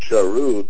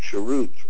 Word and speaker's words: charut: Do not pronounce it charut, but rather charut, for this is the Torah that is called freedom.